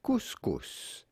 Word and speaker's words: couscous: Simply couscous. – Hey! – Oh, he's saying the second S.